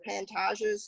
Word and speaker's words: Pantages: I didn't go in, but on our trip, we went to the Pantages Theater.